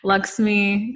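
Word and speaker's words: Lxmi: I think I'm pronouncing it right. It's L X M I, Luxme Cosmetics.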